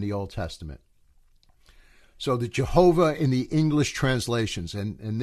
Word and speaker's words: Jehovah: So we have, and I'm going to stick to Jehovah, that pronunciation.